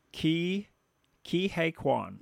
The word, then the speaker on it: Ke Huy Quan: Ki Ke Huy Quan.